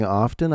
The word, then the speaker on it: often: I guess I've been mispronouncing often. I've been dropping that hard T. Apparently, we're not supposed to. It's often. O-F-T-E-N. Drop the T.